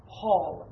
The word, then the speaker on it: Holl: It's pronounced as if it were an A, but it doesn't have an umlaut, so it's not Hurl. It's actually Hall, H-O-L-L, Karl Hall, The Reconstruction of Morality.